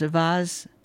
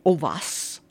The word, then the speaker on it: Auxvasse: Avaz, spelled A-U-X-V-A-S-S-E, pronounced Avaz, in French it would be Ovas.